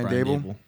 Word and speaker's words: Daboll: I think the Daboll situation, is that how you even say his name? – Yeah, Brian Daboll.